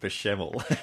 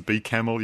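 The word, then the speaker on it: béchamel: I don't know how you've mispronounced bechamel. – Well, I thought it was bee camel, you know, like the little bee and the camel, and I've been getting in some trouble about that for the last 20 minutes or so.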